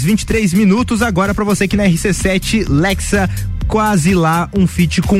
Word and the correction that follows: Lexa: Desculpa, foi Lecha. Lecha? – Lecha. Ah, se pronuncia Lecha o nome dela.